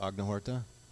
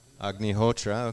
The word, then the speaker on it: Agnihotra: Are you familiar with Agnihotra? – Agnihotra, okay, I said it wrong.